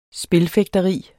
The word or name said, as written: spilfægteri